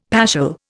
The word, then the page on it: paschal: Indian